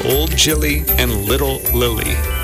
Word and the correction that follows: Gilly: It's Old Gilly. – Old Gilly, excuse me.